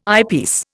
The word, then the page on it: eyepiece: Indian